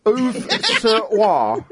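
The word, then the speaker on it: Auvers-sur-Oise: Auve-sur-Oise, I think it's supposed to be. It's spelt Auvers, then hyphen, S-U-R, hyphen, O-I-S-E, alongside the last, oh, for God's sake.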